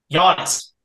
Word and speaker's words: Giannis: Giannis. – Why is there a G? – It's Greek. It's a silent G.